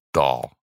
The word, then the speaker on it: Dole: That's pronounced Dahl. – Dahl? – Yeah. Oh, okay. It's spelled, I looked Dole, that one up too. – D-O-L-E, Dole. But it's pronounced Dahl.